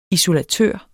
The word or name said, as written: isolatør